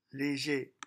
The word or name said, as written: leger